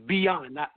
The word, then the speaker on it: Byon: Well, my government name is Byon, B-Y-O-N. Everybody has a problem with pronouncing that. It's almost, I tell people this so they can get it, it's like Beyonce without the say. So you just, it's Beyond, not Byon.